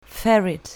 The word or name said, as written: ferret